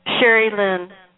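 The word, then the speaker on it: Sharilyn: Sherry Lynn.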